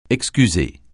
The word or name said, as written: excuser